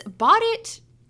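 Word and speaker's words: Bot It: So basically this Bought It, which is, I need to clarify, is spelled BOT-IT. – I just realized that it's a homophone for BOUGHT.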